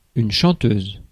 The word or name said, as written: chanteuse